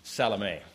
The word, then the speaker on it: Salome: From several verses in the Gospels, it seems reasonably clear that John's mother was, I'm going to pronounce it, well, that's the Spanish pronunciation, Salome. That's the way I'm used to thinking of it, so that's how I'll give it.